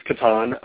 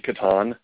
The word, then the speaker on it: Catan: I believe it's Catan. I have heard from the German people from whence the game comes that it is indeed Catan.